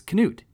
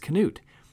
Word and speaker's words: Knut: But Reagan went on the record saying he asked Knut's widow, Bonnie, is it a soft, silent K or is it a hard K? And she said, it's Knut. It's Knut. Americans have adopted the name and just started calling him Knut, which is not true.